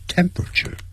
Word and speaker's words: temperature: The temperature，你看这有几个音节，本来是有四个音节 temperature，但实际念的时候 per 要念成 p 的音就好了，变成三个音节 temperature。